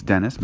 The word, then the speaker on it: Denes: My Instagram handle is Lawrence Dennis, my full name, and that is spelled L-A-W-R-E-N-C-E. And my last name is D-E-N-E-S. So Lawrence Dennis, all one word.